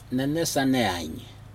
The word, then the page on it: nenes sageiag: Aneityum